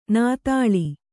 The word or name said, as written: ನದುಳು